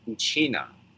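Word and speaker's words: Uchinaa: In the Okinawan language, we pronounce it Uchina.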